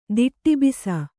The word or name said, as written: ದಿಟ್ಟಿಬಿಸ